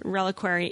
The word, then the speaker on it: reliquary: And by it is reliquary is correct. That is the actual. It's, okay. – I know it sounds really weird, but it's a. – I will always mispronounce it. – It's reliquary.